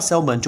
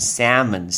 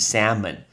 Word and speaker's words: salmon: 听到 salmon 就不对了，因为 L 不能念，要念成 salmon salmon。